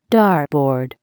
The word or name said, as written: dartboard